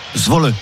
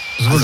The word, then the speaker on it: Zwolle: Zwolle. – Zwolle.